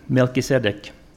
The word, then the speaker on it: Melchizedek: Melchizedek, I asked Daniel to have a look and see how you pronounce it.